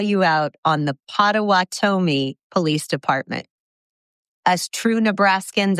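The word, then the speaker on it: Pottawattamie: Potawatomi.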